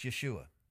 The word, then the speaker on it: Yeshua: It's Yeshua.